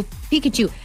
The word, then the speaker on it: Pikachu: In a live-action Pokemon movie, Detective Pikachu. Is that how you say it? – Yeah. – I know Tingy's into Pokemon, right? – He is. Yeah, so Pikachu.